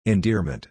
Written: endearment

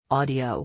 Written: audio

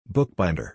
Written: bookbinder

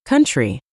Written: country

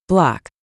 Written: block

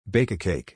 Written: bake a cake

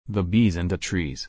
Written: the bees and the trees

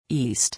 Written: east

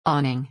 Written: awning

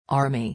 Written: army

